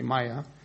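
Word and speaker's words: Maia: And the author is Maya, I can't pronounce her name, so I'll spell it, but her first name is M-A-I-A, M-A-I-A, Maya. The last name is S-Z-A-L-A-V-I-T-Z, and it appeared in January of 2011.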